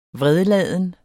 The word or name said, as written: vredladen